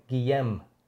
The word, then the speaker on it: Guillem: Guillaume is a French name. Guillem is the way you pronounce it.